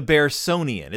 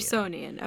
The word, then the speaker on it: Bearsonian: The Bearsonian. It's like the Smithsonian. – The Bearsonian, okay.